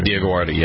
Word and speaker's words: Dioguardi: How do you pronounce it? Dioguardi? – Dioguardi, yeah.